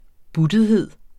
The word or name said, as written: buttethed